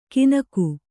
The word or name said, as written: ಕಿನಕು